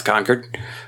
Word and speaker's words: Concord: I said Concord, North Carolina. Everybody's like, no, it's Concord.